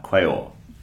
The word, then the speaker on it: Quaoar: So it's spelled Q-U-A-O-A-R, quail.